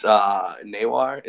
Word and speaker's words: Noir: So it's like your last name, Noir? – Yes, we can smell them from across the internet. – It's Nawar. It's Drakkar Nawar.